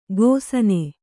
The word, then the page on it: ಘೋಸನೆ: kannada